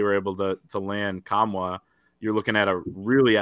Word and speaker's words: Nkamhoua: Former Tennessee por- uh, forward Olivier Nkamhoua, uh, which is how you pronounce it because I researched it, uh, spelled N K A M H O U A.